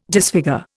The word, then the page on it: disfigure: Indian